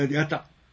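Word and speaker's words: 箭: あ、やた、あ、これやたって読むんですか。これやたって読むんですね。 – はい、そうです。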